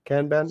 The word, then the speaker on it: Kanban: Kanban? – Yes.